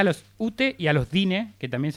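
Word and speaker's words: Diné: Ahí está, pronunciado Dine, no.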